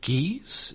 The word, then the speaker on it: Ghis: They bring us to see the captain of the Leviathan, which is a judge by the name of Geese. Is that how you pronounce it? – Yeah, I think so. – G-E-I-S? – G-H-I-S? – G-H-I-S, is that how it is? – Yeah.